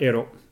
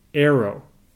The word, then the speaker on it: Arrow: His name is not Ero. I promise. His name is Arrow.